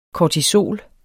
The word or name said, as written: kortisol